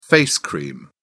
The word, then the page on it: face cream: British English